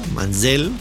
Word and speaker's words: Manziel: Y, siguen apostando a Manziel, Manziel, como se pronuncia en inglés.